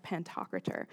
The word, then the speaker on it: Pantocrator: Christ Pantocrator, which means ruler over all or Lord Almighty.